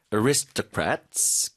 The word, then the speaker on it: aristocrats: So fine dining has its roots in the dining culture of French aristocrats. 귀족이란 뜻이죠. 그 발음 좀 도와드릴게요. – Aristocrats. – Aris, 이음절 힘주시고, aristocrats, 한번 더요? – 네.